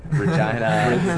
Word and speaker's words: Regina: George and I used to play in bands in the scene of our hometown, Regina. – Mm-hmm. Excuse me, what was that? – Regina, Saskatchewan.